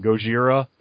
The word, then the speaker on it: Gojira: Gojira.